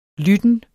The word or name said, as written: lytten